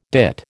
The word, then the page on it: dit: American English